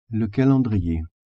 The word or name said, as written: calendrier